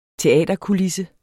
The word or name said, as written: teaterkulisse